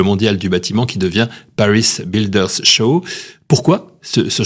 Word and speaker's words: Paris Builders Show: Je prononce comme ça, Paris Builders Show.